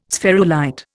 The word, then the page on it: spherulite: Indian